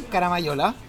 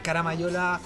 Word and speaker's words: Caramagnola: Em, Estamos en Caramayola, ¿cierto? Eh, Caramayola eh, los, pueden encontrar por el Instagram, por supuesto. Arroba Caramayola, se escribe Caramagnola.